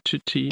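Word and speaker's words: Ncuti: Nuti, um, how do you say it again? Tuti. Tuti. Gatway.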